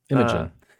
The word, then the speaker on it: Imogen: Is it Imogen? Is that how you say it? – Imogen? I think so. Yeah. Imogen.